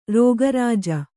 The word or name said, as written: ರೋಗರಾಜ